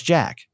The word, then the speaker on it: Jacques: Oh, it's Jack. – Oh, it's Jack. – Yep. Jack. Okay. We're going with Jack.